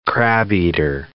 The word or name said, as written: crabeater seal